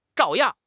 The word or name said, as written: 照样